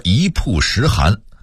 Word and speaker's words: 曝: 比如说一曝十寒，在这个词里这个字一定要念曝，晾晒的意思，事情曝光的时候读曝，胶片曝光的意思，所以要区别对待。